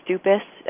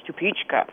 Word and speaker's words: Stupice: A lot of people pronounce it Stupice, S-T-U-P-I-C-E, but it's Czech, so it's pronounced Stupička.